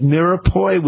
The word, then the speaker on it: mirepoix: Gill's Onions has already issued a recall for the products, which include diced yellow onions, diced onions and celery, diced, am I pronouncing this right, mirepoix, which is onions, carrots, and celery, and diced red onions. – You got this.